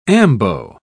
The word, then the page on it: ambo: British English